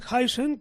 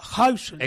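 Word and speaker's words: Huijsen: Y se ha llamado, como dices, a Dean Huisens, que es una, se pronuncia así, porque tengo un amigo holandés de la infancia. – Sí, espérate, que para que te crean, he cogido. – Se pronuncia Huisens.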